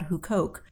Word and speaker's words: Huqoq: My current dig is at an ancient Jewish village in Galilee, very close to the Sea of Galilee, called Huqoq, which it looks weird when you spell it. It's spelled H-U-Q-O-Q.